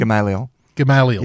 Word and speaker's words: Gamaliel: Gamaliel.